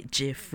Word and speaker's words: GIF: I like to say GIF with a soft G.